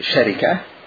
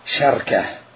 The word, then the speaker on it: شركة: الشركة في كلام العرب تنطق الشركة، تقول شركة، ولك أن تقول شركة بفتح الشين وسكون الراء، ولك أن تكسر الشين تقول شركة، فهي شركة وشركة وشركة.